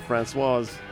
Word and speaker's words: Françoise: And once again, I've mispronounced the Françoise Hardy. There you go, Françoise.